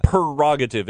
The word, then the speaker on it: prerogative: It is prerogative. P-R-E. – Well, everybody pronounces that one wrong. – Prerogative. It's pre. P-R-E. Prerogative. – Nobody pronounces the first R. – I know. I'm just helping you out. – Prerogative, because pre means before.